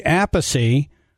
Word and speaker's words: Appice: You know, you told me in an interview that your last name is actually Appice, but it was too complicated to tell people it's a piece, so I wanted to honor you with the correct pronunciation of your name.